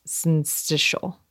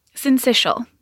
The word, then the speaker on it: syncytial: And I hope you're going to tell me how to pronounce syncytial. – Syncytial. Yep, syncytial. You got it.